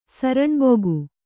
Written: ಸರಣ್ಬುಗು

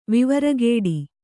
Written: ವಿವರಗೇಡಿ

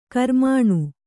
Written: ಕಾರ್ಮಣ

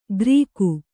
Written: ಕರಿಕು

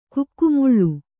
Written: ಕೊಕ್ಕೆಮೊಳೆ

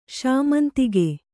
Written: ಶಾಮಂತಿಗೆ

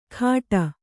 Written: ಖಾಟ

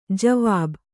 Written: ಜವಾಬ್

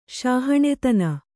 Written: ಶಾಹಣೆತನ

